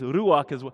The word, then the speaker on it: ruach: I'm butchering your language, but it's ruach is how you would say it in Hebrew, and how I would say it.